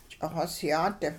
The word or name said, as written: ch'ixosyaat